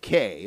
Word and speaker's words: Kei: They were called Me and K. It was spelled M-I-E and K-E-I, but you pronounced it Me and K.